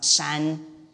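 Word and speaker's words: shan (山): And then the middle picture here is mountain, it's pronounced shan.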